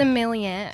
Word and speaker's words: sommelier: At 18, being a similar, how do you say it? – Sommelier. – Sommelier.